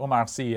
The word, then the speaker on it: Omar Sy: So Omar Sy, and so the New Yorker has this a profile of him, and he's the actor in Lupin, the well-known Netflix series.